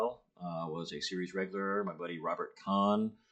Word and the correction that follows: Kahn: pardon me, Robert Can. I've only recently learned I've been mispronouncing his name for 10 years.